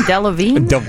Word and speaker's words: Delevingne: How do you say her name? Delevingne. – Yeah.